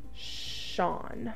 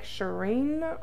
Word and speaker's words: Shaurne: And that's how you say it, but I love that it's spelled different than the Australians. Sean is spelled S-H-A-U-R-N-E. And I was like, Shireen or something the entire time.